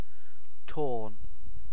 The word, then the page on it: torn: British English